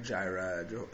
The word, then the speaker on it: Jireh: Yeah, God, you wouldn't pronounce the J in Jireh either. That's a mispronounce.